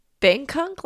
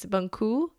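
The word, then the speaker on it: Bengkung: It's not Bangkok, like I've been saying, it's Bangku belly binding.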